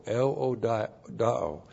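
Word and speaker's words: euodoō: That's E-U-O-D-O, long O, el odao.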